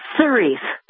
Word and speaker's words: Cerise: Yes, Cerise. – Cerise. – No, no H.